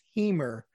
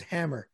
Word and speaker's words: Hamer: Um, famed voting rights activist Fannie Lou, I think it's pronounced Hamer, mm. thought it was Hammer.